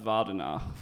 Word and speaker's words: Wardenaar: If you want to make fun of it, the Dutch pronunciation, I'm told, I'm not Dutch, but is Vardenaar.